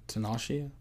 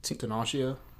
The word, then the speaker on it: Tinashe: Tinashe? – Yes. Tinashe.